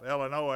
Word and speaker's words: Illinois: Jacob and I are going to be leaving next week to go to Illinois. Did I say that right? I left the S off. Illinois, everybody told me I better get it right before I get there.